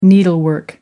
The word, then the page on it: needlework: American English